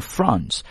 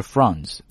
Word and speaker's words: France: 好，拉回来 ,in France, 大家听我读的是 France, 但是呢很多美式英语会读 France,France 或 France 都可以，关键那个 A 字母的发音，对吧。